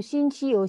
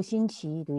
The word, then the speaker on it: 星期一: I want to mention here they pronounce like that. 星期一 correct? But I have a habit doing second tone. 星期 okay? Are you clear? You can do 星期一 or 星期一 do you get it?